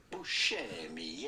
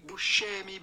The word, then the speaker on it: Buscemi: Steve Buscemi. Yes, that's the way it would be said in Italy. Steve Buscemi, because C in Italian is pronounced like CH.